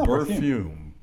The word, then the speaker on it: Berthume: It's Berthume, so it rhymes with perfume. – Oh, Berthume.